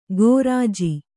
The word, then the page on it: ಕೋರ್ಜಿ: Kannada